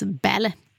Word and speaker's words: Balle: Ja, och Solveig heter, det stavas B-A-L-L-E, men uttalas Belle.